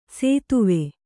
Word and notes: ಸೇತುವೆ: sētuve

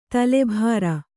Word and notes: ತುಲಾಭಾರ: tulā bhāra; kannada

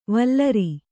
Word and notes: ವಲ್ಲೂರ: vallūra

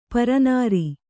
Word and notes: ಪರನಾರಿ: para nāri

